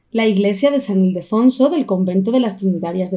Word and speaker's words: Ildefonso: Este proyecto lo empezaron, y a ver si soy capaz de decirlo de seguido, en la iglesia de San Ildefonso del convento de las Trinitarias Descalzas de Madrid. Creo que lo he hecho bien. – Ildefonso. – ¿Cómo se dice? – Lo has dicho raro. Es Ildefonso.